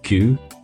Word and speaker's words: queue: How do you English pronounce the word that is spelled Q-U-E-U-E? – We pronounce it Q. – Right. That's how that word, with a single consonant followed by no less than four vowels in a row, beginning with the letter Q, is pronounced in English, is it? – Correct.